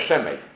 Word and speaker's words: Szemej: Gdy spojrzymy na to imię po hebrajsku, to ono po hebrajsku się wymawia Szemej.